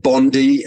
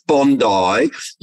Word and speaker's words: Bondi: And I like that line where the American guy said Bondi and she said, no, it's Bondi.